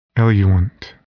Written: eluent

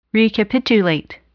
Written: recapitulate

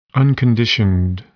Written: unconditioned

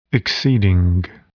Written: exceeding

